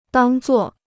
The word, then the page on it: 当作: dàng zuò